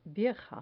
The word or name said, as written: vieja